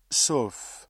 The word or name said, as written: sauf